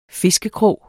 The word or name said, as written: fiskekrog